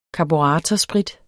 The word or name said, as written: karburatorsprit